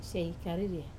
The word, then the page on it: sai kariri: Wamesa